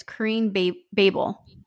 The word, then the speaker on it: Kareen Boebel: Mispronounces it. It's Kareen Boebel.